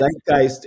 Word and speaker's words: Zeitgeist: Is it Zeitgeist? – Yes. Zeitgeist is a German word that means the spirit of the time.